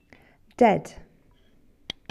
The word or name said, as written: dead